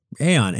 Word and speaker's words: Aon: Now, am I saying that right? Aon? – Yeah, sure. – Aon and Willis Towers Watson.